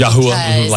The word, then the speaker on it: Yahweh: Yahuwah, like yawning. – It really is supposed to sound like... – mm-hmm. – Yahweh. – Like breathing.